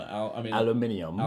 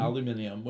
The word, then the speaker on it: aluminium: I mean, British people pronounce aluminum. – Aluminium. – Aluminium, which is the correct way to say it because we.